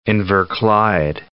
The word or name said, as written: Inverclyde